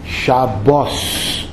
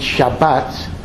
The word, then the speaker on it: Shabbat: So in Ashkenazic Hebrew, we would call the day Shabbos. However, the state of Israel, for very interesting historical reasons, has adopted the Sephardic pronunciation. So in spoken Hebrew, it's Shabbat. However, Ashkenazim still preserve the extinct Ashkenazic pronunciation for prayer and for Torah reading. So although if I would be talking to an Israeli, I would say Shabbat. When I read my prayer book, I say Shabbos.